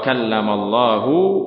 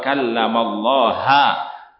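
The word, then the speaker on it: kallamallahu (وكلم الله): Mereka mengatakan bahwasanya ini bacanya bukan wa kallamallahu Musa taklima, tetapi wa kallamallaha Musa taklima. Allahnya bukan fa'il, bukan hu, tetapi ha, kata mereka.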